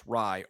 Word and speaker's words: Raih: Yeah, I mean, it's David Rye. Is that how you pronounce it, by the way? – Yes, it's spelled R-A-I-H, but apparently it is pronounced Rye, R-Y-E.